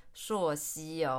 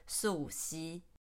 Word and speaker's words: 溯溪: 溯溪我也只有听过而已，没有玩过，感觉蛮有趣的。去过的人都说很好玩。那溯溪你在台湾可能会比较常听到，大家把它念成溯溪哦，但是字典上正确的读法是溯溪。